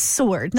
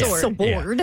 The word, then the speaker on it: sword: Well, no, you can pull it up right now, and it's S-O-R-D is how you pronounce it. It's sword, not sword.